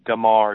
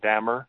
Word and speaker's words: Damar: And also, this man's name is Damar. It's not Dammer.